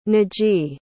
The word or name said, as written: najee